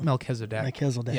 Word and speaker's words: Melchizedek: And so this, so Melchizedek, if I'm not saying it correctly. – Melchizedek.